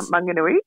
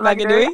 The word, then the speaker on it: Maunganui: Mount Maunganui. – Mount Maunganui. I can't say that. I'm so sorry. – Mount Maunganui. Yeah, Mount Maunganui.